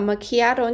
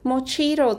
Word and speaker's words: macchiato: 有一天 Antonella 问我为什么你们台湾人都把 Macchiato 念成 Mochito 之类的东西呀、啊？